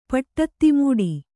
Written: ಪಟ್ಟತ್ತಿಮೂಡಿ

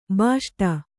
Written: ಭೋಸುಡಿ

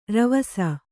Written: ರವಸ

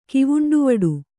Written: ಕಿವುಂಡುವಡು

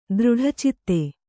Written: ದೃಢಚಿತ್ತೆ

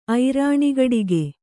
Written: ಐರಾಣಿಗಡಿಗೆ

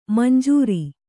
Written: ಮಂಜರಿ

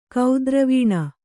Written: ಕೇದಾರವನ

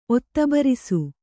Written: ಒತ್ತಬರಿಸು

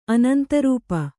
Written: ಅನಂತರೂಪ

